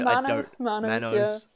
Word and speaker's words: Manos: Is it Manos? – Yeah. – Is that how you pronounce it? – Manos.